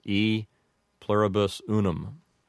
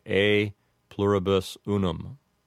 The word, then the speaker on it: E pluribus unum: The first one you would commonly hear said by an English speaker as E pluribus unum. And that's not technically correct because the first letter, the letter E there, would be, I believe, a long E, so it would sound like A. And so that expression would sound like A pluribus unum.